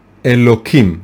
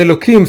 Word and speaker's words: Elohim: And the second name is, of course, Elohim, right? It's written with an H, but we pronounce it with a K.